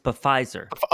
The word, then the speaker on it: Pfizer: Oh, it's Pfizer. – Oh, I'm sorry. – I always pronounced it Pfister.